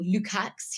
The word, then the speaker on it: Lukacs: I really enjoyed Paul Lukacs. He's Hungarian, so it's spelled L-U-K-A-C-S.